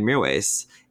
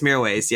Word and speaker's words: Mirwais: Is that how you pronounce his name? – Yeah, I think it's Mirwais, yeah.